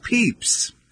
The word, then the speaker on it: Pepys: This is in part because Pepys, pronounced Peeps,